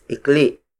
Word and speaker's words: ikli: The opposite of haba is ikli. Ikli. Ikli. Ikli is spelled as I-K-L-I. Note the glottal stop at the end. Ikli, not ikli.